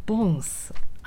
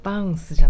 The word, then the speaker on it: bounce: とですねそうボンスあえてバウンスじゃなくてボンスって発音したのは今日はジャマイカのレゲエの話をしたいからです